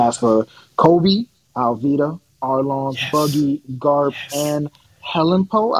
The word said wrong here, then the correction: Helmeppo: I never can pronounce that dude's name. – Helmeppo. – Thank you.